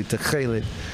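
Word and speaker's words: tekhelet: they know I pronounced it correctly, techelet, you know, and none of that.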